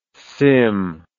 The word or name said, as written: SIMM